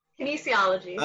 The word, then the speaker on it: kinesiology: Kinesthesiology, is that right? – Kinesiology. – I wasn't even close.